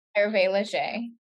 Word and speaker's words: Hervé Léger: It was like I'm going to say it wrong, but it's like the Hervé Léger. Is that how you say it? – Hervé Léger.